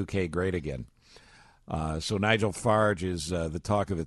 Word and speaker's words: Farage: Spelled like Farage or Farage. – Yeah. – Pronounced Farage.